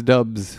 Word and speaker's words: Doubs: But I looked up the pronunciation, and it's Dubs. – Come on, is it Dubs, really? – It's Dubs, really, I swear.